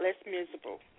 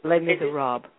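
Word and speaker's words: Les Misérables: Let's talk about Les Miserables. I hope I pronounced it right. This musical. – Les Miserables. – Miserables. Okay, thank you for correcting me.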